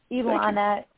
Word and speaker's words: Ilana: It's Ilana, like Ilana, but with an L.